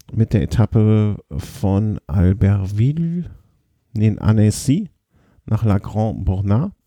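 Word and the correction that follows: Le Grand-Bornand: oder irgendwie so ähnlich ausgesprochen. – Le Grand Bornand, ja.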